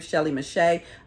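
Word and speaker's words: Shelley Mechette: It's my name, Shelly Mache. I'll spell that. It's S-H-E-L-L-E-Y-M-E-C-H-E-T-T-E.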